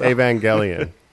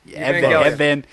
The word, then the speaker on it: Evangelion: Well, do you know anything about Neon Genesis Evangelion? – Oh, yeah. – Am I saying that correctly, also? – Evangelion. – Evangelion.